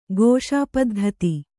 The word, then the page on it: ಘೋಷಾಪದ್ಧತಿ: ghōṣā paddhati